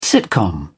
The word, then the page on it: sitcom: British English